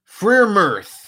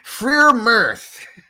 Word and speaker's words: Freiermuth: Freiermuth. That's how you say it. Freiermuth.